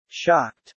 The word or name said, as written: shocked